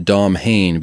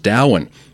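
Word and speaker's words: Domhain: And to finish off the triplet of world tripping, we had, I was initially going to pronounce it Dom Hain because I am not from Ireland, but supposedly you look that one up and it's pronounced Dowan, kind of like Samhain, which I would have pronounced Sam Hain, because again, uncultured Italian-Austrian man right here.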